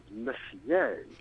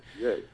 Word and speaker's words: Messier: I make one little correction, that the astronomer's name is Charles Messier. – Messier, okay. – Messier. – So it was my pronunciation that was Messier. – Yes. – But it's spelt the same way, except he's French. – He's French. – Yeah, so being French. And if that were my name, I would be using the French pronunciation anyway.